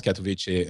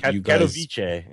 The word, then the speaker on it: Katowice: I think it's pronounced Katowice. – Katowice, yes.